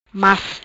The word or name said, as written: Mas